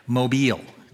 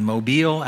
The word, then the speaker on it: Mobile: Mobile, down in Mobile, Alabama.